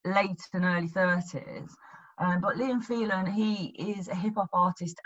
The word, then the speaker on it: Liam Phelan: So your last song on this list is called "Insane" by Liam Phelan, if I've said that correctly. – That's right. Yeah, Liam Phelan, and he's a great example.